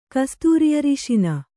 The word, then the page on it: ಕಸ್ತೂರಿಅರಿಶಿನ: Kannada